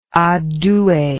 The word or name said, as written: adue